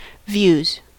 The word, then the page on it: views: American English